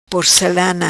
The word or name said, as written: Porcelana